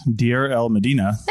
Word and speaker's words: Deir el-Medina: The only other large cache of ostraca has been found in the ancient village of, I don't know how to say this, Deir el-Medina.